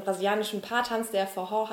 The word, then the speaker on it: Forró: Man hat mir erklärt, dieser Tanz Forró, also Betonung hinten und in der Mitte wird gesprochen wie ein H. – Ja. Geschrieben wird es Forro, also F-O-R-R-O. – Ja, genau, mit ähm, Akzent was, am Ende.